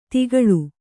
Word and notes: ತಿಗಳು: tigaḷu

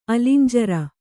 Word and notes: ಅಲಂಜರ: kannada

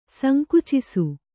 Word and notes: ಸಂಕುಚಿಸು: sankucisu

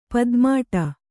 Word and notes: ಪದ್ಮಾಟ: padmāṭa

